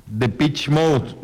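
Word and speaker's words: Depeche Mode: Dice, se pronuncia The Pitch Mode, no The Pitch.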